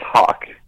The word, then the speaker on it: Howk: It's Hawk, like H-A-W-K, but it's spelled H-O-W-K because I don't really know why. I think my great-grandfather had a weird sense of humor. But yeah, it's very Swedish and it's very phonetically inaccurate.